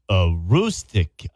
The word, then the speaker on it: Aroostook: Aroostook. – You have a great day. – Thank you. Thank you so much for your help. – Yes. Bye. – Bye-bye. Aroostook County. Aroostook.